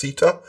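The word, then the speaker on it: CTER: There'll be a new commission for tertiary education and Wales, which is officially pronounced CETA.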